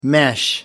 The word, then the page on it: mesh: American English